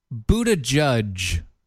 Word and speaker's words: Buttigieg: You mean like, hey, Pete Buttigieg, which is apparently how you pronounce his name.